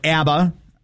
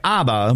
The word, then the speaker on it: ABBA: If you are a fan of the music from the 70s era, specifically of the Swedish band ABBA or ABBA, I guess it's properly pronounced both ways. I go by ABBA, but I think a lot of people like to refer to that quartet as ABBA.